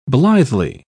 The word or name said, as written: blithely